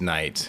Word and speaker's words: Nite: Traditionally, it is Knight, okay. – But if you want to get disowned from your family, it's Nite.